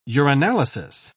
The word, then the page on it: urinalysis: British English